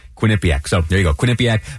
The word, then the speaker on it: Quinnipiac: Also Quinnipiac, which may I just point out, I am very proud to know how to pronounce uh, Quinnipiac.